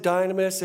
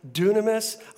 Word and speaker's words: dynamis: We pronounce it dynamis. If you look it up this afternoon, it's actually pronounced dunamis. I don't know why, it just is.